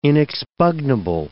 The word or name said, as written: inexpugnable